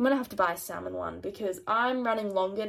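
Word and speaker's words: Salomon: So I don't want to buy it yet, but I have seen the brand Salmon, Salmon, so I don't know. I feel like Americans make things that aren't their name sound different, but I think it's pronounced salmon like the fish.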